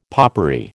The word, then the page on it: popery: American English